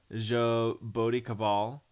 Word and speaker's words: Jaboticabal: So in 1965 in Jaboticabal, Brazil. – Yeah, that's right. That's actually exactly how you pronounce it. Jaboticabal. That's pretty close.